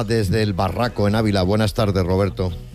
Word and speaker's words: Barraco: El Barraco.